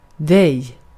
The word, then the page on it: dig: dɛj